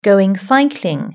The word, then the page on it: going cycling: British English